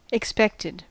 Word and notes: expected: American English

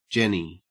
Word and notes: jenny: Australian English

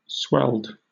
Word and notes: swelled: British English

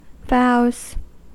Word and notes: vows: American English

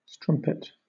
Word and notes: strumpet: British English

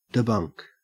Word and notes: debunk: Australian English

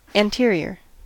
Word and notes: anterior: American English

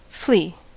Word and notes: flea: American English

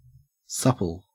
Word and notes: supple: Australian English

